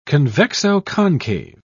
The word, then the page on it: convexo-concave: British English